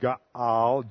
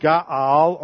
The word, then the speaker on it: ga'al: And or the verb ga'al, G-A-A-L, G-A apostrophe A-L, means redemption. And usually the word ga'al or go'el emphasized a kinsman redeemer.